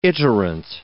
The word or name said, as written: iterant